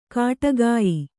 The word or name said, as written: ಖಡಗಾಯಿ